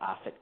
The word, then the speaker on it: Offit: Akaren, A-K-A-R-E-N, at Offit Kerman. That's kind of long, but it's spelled like the words off it, and then K-U-R-M-A-N.